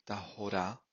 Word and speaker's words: Tahora: We are commencing Be'ezrat Hashem, the book of Tahara. More appropriately, I think it should be pronounced Tahora, like the Pasuk, Ubim Lot Yemei Tahora.